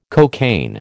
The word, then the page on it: cocaine: American English